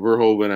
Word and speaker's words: Verhoeven: Verhoeven, I believe is how you pronounce the name.